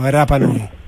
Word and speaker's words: Rapa Nui: No, pues sin la R. – La R pronunciada con amor, María José. – Rapa Nui. Como una R, por así decirlo, Rapa Nui.